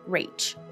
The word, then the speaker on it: Rache: spells out R-A-C-H-E, Rache, or I believe it's pronounced Rache. It is a German word for revenge, and Sherlock Holmes has realized this when everybody thinks it was short for Rachel.